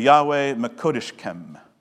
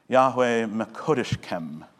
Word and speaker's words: Yahweh Mekodeshkem: But it's pronounced this way, Yahweh Mekodeshkem. Yahweh Mekodeshkem.